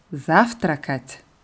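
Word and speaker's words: завтракать: Saftrak und das Verb frühstücken ist Saftrakat. – Saftrakat. – So wie das Verb Stavat endet das Verb Saftrakat auf at. Es wird also fast genau gleich konjugiert. Saftrakat wird auf der ersten Silbe betont.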